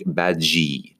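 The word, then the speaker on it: Badguy: And his name is pronounced Dominic Badgie.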